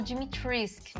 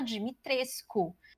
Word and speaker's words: Dimitrescu: Eu vi que a apresentadora do, do showcase, ela falou Dimitrisk, né? Mas ela é americana. Então, eu, eu vi depois até o pessoal que, que é lá do leste europeu falando que se pronuncia Dimitrescu. Tipo, bem forte, Dimitrescu.